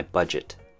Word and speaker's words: budget: Break this down into two syllables, budget. Has more of a J sound on the G-E-T part, budget.